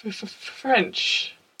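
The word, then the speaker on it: Ffrench: Well, her name's Caitlin, I know that much, but her surname, I want to pronounce it French. – Yes, there is more than one F at the beginning.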